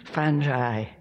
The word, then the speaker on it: fungi: That's how she pronounced it, so I'm sticking to it, fungi.